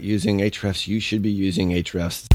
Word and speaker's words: Ahrefs: That's how it's spelled, but Ahrefs is how it's pronounced.